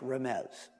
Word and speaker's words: remez: I have it with a Z. It's actually usually transliterated with an S, but it's pronounced remez.